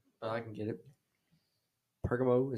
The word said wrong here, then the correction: Pergamum: is that how you say it? – Pergamum, I think.